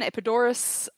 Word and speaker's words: Epidaurus: So I never know the right pronunciation. I think in English it's got a V and in Greek it doesn't. – Yeah. – I'll go with the Greek one, Epidaurus.